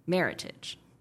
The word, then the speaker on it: Meritage: So Meritage was created combining the words merit and heritage, and it's definitely supposed to be pronounced Meritage, not Meritage.